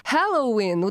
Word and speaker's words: Halloween: Но если мы посмотрим транскрипцию, то Halloween ударение падает на первый слог, а hello ударение падает на второй слог.